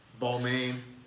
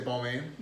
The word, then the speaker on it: Balmain: Balmain, Rude. – Balma. – Is that what it says? Is that how you say it? – Yeah. – I don't say Balmain? – No.